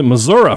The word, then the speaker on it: Missouri: Next question here comes to us from Jay Hammers, who wants to know, how do you say Missouri? Like that. – Missouri. – That's how I say Missouri. – Yep. – My mother-in-law pronounces it Missouri for some reason. That's a pretty common thing. I don't know why it is, but it is.